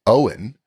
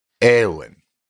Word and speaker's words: Eoin: I always stumble, for instance, on the Irish name Owen, which is spelled E-O-I-N. And I always think it's like Eowyn.